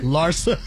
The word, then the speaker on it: Larsa: Larsa. That's how you say her name.